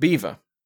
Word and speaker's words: Belvoir: The Witches of Bever, which is spelt Belvoir, but which apparently was too Norman for the Anglo-Saxon locals to pronounce as such, is in the county of Leicester.